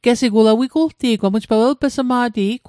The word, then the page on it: welp'smat: Mi'gmaq